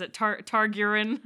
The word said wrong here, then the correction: Targaryen: Tar Targaryen the dragons.